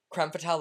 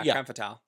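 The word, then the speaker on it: Crème Fatale: It's Creme. – Creme? – Creme Fatale, like Femme Fatale? – Yeah. Creme Fatale. – I guess you're right.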